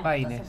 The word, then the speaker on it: Payne: No, era P-A-Y-N-E. – Ah, con Y. – Paine, sí, con Y.